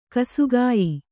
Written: ಗಾಸಿಗೆಯ್

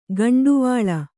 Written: ಗುಂಟವಲೆ

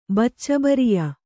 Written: ಬಚ್ಚಬರಿಯ

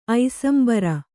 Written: ಐಸಂಬರ

